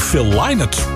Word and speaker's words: Phil Lynott: Gaan wij naar Phil Lynott? Want zo spreek je het uit volgens de familie, hè?